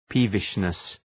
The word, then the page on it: peevishness: ‘pi:vıʃnıs